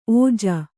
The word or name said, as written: ಓಝ